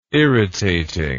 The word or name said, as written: irritating